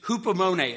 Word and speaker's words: hupomone: The word is hupomone. It's spelled H-U-P-O-M-O-N-E.